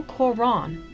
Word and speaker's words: Quran: The title Quran, pronounced Quran in Arabic, is derived from the word qara'a, which means to read or recite.